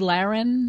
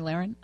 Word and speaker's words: Lahren: Tommy Lahren? – Yes. – Tommy Lahren.